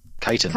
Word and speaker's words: chitin: Chitin.